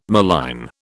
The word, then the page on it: malign: British English